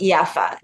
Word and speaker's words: Jaffa: I don't know if it originated in Palestine, but it's pronounced Jaffa. Jaffa, like the J is a Y.